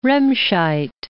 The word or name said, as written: Remscheid